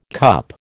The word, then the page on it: CUP: American English